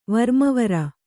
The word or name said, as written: ವರ್ಮವರ